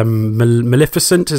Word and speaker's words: Maleficent: Maleficent.